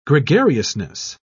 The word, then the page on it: gregariousness: British English